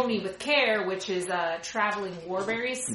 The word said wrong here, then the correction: Wilburys: Wilburys. Wilburys. Yeah.